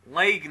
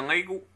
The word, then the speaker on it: Leigh: L-A-G? – L-E-I-G-H. – Leigh Nash. É isso. Leigo. Em vez de ser leigo, é. Em vez de ser O no fim, leigo. – Ah, é assim? – Leigh Nash.